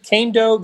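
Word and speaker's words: Kaindoh: Joshua Kando, do we pronounce it Kando? That's something else that we learned during draft week is how to pronounce some of these players' names. – That's how the Chiefs have been pronouncing it, so that's what we're going with.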